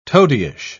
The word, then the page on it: toadyish: British English